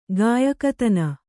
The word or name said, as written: ಗಾಯಕತನ